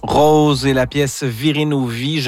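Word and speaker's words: Rau_Ze: Rose, c'est le duo formé de Rose Perron et Félix Paul. Ça s'écrit R-A-U, barre en bas, Z-E, et c'est très, très bon.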